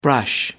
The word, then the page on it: brush: American English